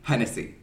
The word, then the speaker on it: Hennessy: Is that how you say your last name? – Hennessy. – Hennessy. Okay, yeah. Perfect. – Really just like the cognac for any of our drinkers out there.